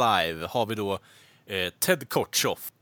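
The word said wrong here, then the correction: Kotcheff: Ted Kortcheff, förlåt mig, jag och mina uttal här.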